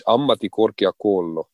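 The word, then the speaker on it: ammattikorkeakoulu: Ammatti korkeakoulu.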